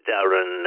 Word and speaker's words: Darren: The call sign is Mexico number 3, Papa Victoria X-ray, and my name is spelt Delta Alpha Romeo Romeo Echo November. It's Darren.